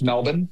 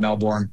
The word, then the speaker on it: Melbourne: In Melbourne, you think that this is going to happen? – Isn't it? Is it pronounced Melbourne? – Well, I mean, if you want to go with the accent, Melbourne.